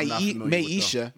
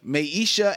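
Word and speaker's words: Maiesha: I don't so that may be Mai- Maisha, Maisha, and the Hip Huggers.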